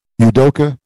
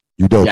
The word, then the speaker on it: Udoka: oh man, how do you say the coach's name? I don't want to mess it up. – Udoka. Udoka. Yes.